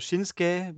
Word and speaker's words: Shinsuke: also Shinsuke, wobei ich jetzt wieder gelernt habe, das U spricht man also nicht, bei Asuka ja auch nicht.